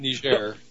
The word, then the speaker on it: Niger: It's actually pronounced now, Niger.